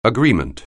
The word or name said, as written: agreement